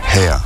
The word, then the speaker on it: H31R: qui se prononce Hair tout simplement.